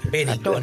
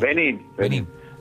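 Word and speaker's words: Benín: Aparte, un partido tan difícil como con Benin, calculo que lo querrá tener. – Benin. Con el acento en la I. – Benin.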